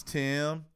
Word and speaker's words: Tim: And she was just like, or I was like, my name is Tim. – Yeah. – It rhymes with Jim.